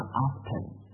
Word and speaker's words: often: 好，那么 very often, often 的意思我们知道，在英语里就是 often，但很多美文读成什么呢？读成 often, O F T E N，读成 often，音啊，我们说的不发音叫 often, often，是吧？OK，好，那么 next one, let's say。